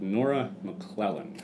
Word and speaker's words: Nora McLellan: So with Nora McLellan.